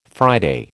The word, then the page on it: Friday: American English